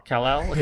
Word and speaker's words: Kal-El: Kal-El.